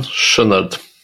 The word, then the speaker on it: Chouinard: Я говорю про фаундера Патагонии, Ивона, как он, Шонард, как правильно произносится его фамилия? – Сейчас у меня он где-то был записан. Да, значит, вообще... – Ион Шонард.